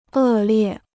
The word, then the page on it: 恶劣: è liè